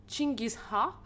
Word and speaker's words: Gengis Khan: Et en fait, sans Gengis Khan, et donc j'ai été appuyée sur la prononciation Google, et ça se prononce visiblement Chingis Ha modestement autoproclamé souverain universel, grand conquérant et fondateur pardon, de l'empire euh, mongol.